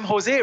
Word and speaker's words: Jose: Well, I'm Jose Raymond. The Jose in my name is spelled J-O-S-E.